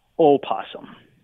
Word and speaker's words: opossum: So I guess the correct version is opossum. – So the correct way to say the name of this creature we're going to be talking more about is opossum. All right, so it's not necessarily a silent O.